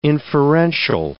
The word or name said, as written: inferential